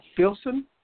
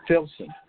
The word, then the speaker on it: Filsan: Filson. S. F. – Filson? – Yeah, it's an F, right? – Yeah. – How you spell it? – F-I-L-S-A-N. – Oh, I thought it was S.